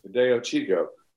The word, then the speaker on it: Fideo Chico: Now here's the guy at the other end of the spectrum, and am I pronouncing it correctly? Fideo Chico? – Fideo Chico. Yeah, which means little sausage or something.